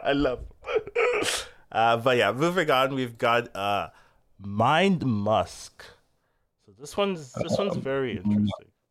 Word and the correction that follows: Mind Muck: Why did I pronounce it wrong? – That's fine. Muck is fine. Muck. You're good. – Mind Muck. Okay, fine.